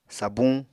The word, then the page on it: sabuñ: Apatani